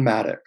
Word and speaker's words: Madoux: Um, you are pretty close with the pronunciation. Um, Dylan Madoux.